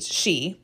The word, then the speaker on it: sith: In Scotland, the word for fairy is S-I-T-H, she, pronounced the same way.